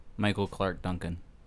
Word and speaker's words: Michael Clarke Duncan: Did I say his name wrong? – Michael Clarke Duncan.